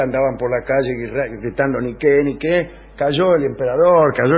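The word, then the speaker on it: Niké: Quiere decir victoria, no se dice Nike, se dice Niqué, y por eso se llaman así las zapatillas, porque quiere decir victoria.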